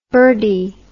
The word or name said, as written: birdy